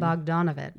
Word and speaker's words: Bogdanovich: And then at one of these parties, the very famous director, Peter Bogdanovich. Did I say that right? – Right, you did. – Bogdanovich.